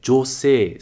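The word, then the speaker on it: josei (女性): Muito importante com essa pronúncia, não prolonga o jo, ok? É josei, sem prolongamento, josei.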